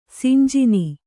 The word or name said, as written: ಸಿಂಜಿನಿ